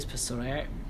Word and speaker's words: psoriasis: psoriasis, I don't know what that is, psoriasis. – Psoriasis. Is that a silent P? – Yeah, like yeah.